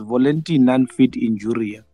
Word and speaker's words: volenti non fit injuria: you pronounce it correctly by the way it's volenti non fit injuria it's it's actually a it's actually a provision in a contract where sports players uh, they signed to.